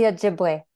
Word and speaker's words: Ojibwe: No, I'm going to butcher this as well. Awobi tribe, Ojibwe. No, how do you say that? – The Ojibwe. – The Ojibwe tribe.